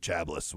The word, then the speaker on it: Chablis: The Jug of Chablis, which is very often a sweet white wine, which is hilarious once we taste these wines today. Chablis is how it's properly pronounced.